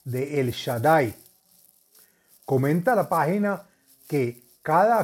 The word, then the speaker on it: El Shaddai: Se escribe Aleph Lamet, Shin Dalet Yud y se pronuncia El Shaddai.